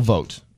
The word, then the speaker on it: Vought: So he's selected Russell Vought, is how you say it, to be the deputy White House budget director, potential budget director.